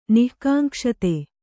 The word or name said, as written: ನಿಃಕಾಂಕ್ಷಿತ